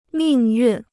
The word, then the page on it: 命运: mìng yùn